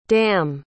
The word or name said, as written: damme